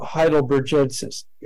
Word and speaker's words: heidelbergensis: Why does Homo heidelbergensis, or gensis, or gensis? How do you pronounce it? – Hard G.